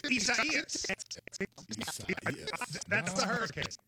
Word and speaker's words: Isaias: I-S-A-I-A-S. – And it's four syllables. I do know that. I-S-A-I-A-S. And it's like I-S-A-I-A-S.